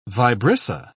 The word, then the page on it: vibrissa: British English